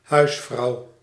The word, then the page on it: housewife: /ˈhaʊs.waɪf/; [ˈhaʊs.ˌwaɪf]